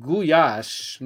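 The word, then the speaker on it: gulyás: Actually, it's pronounced goulash, not lash. It's a Y sound, like goulash, like yo. Because in Hungarian, the L and the Y together make a y sound. So it's spelled, looks like, you know, G-U-L-Y-A-S-S.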